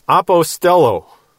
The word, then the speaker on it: apostello: But it's connected to the Greek verb apostello. Apostello, which does mean sent. However, there's another word for sending, pempo. Apostello is A-P-O-S-T-E-L-L-O. And you can put a line over that second O, that's a long O. Pempo is spelled P-E-M-P-O.